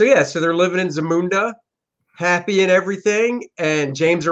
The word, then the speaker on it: Zamunda: How do you pronounce Zamunda. it? Zamunda.